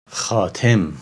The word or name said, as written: Khatem